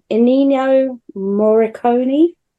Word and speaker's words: Ennio Morricone: Forgive my pronunciation. Ennio Morricone. Uh, he Ennio did the, Morricone. – That's it. Okay.